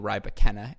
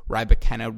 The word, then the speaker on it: Rybakina: That being said, again, something I know we have all enjoyed during this 2020 season is the emergence of Elena Rybakina. And to clarify, it's not Rybakina. I know it's spelled R-Y-B-A-K-I-N-A, but she made that clear. It is not Rybakina.